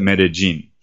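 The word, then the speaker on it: Medellín: Anytime you have a double L in this region, in this accent of the people that live here, they pronounce it as a J sound rather than an L, like a Y sound, like okay. In classic Spanish. So they call it Medellin.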